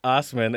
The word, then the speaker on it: Ossman: Name David Ossman, spelled Assman. pronounced Osman, and this article is sassy.